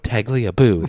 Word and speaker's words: Tagliabue: Tagliabue? – Tagliabue.